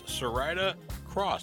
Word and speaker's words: Soraida Cross: Our guest is Soraida Cross.